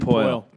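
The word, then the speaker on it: Poile: David Poyle.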